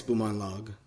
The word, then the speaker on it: Bumanglag: And they laughed when she said it again, you know, and they said, no, that's not how your name is Bumanglag. There's no G at the beginning.